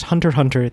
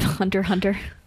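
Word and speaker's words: Hunter x Hunter: It's pronounced Hunter Hunter. The X is silent. That's stupid. – That's the worst possible option. – Hunter Hunter.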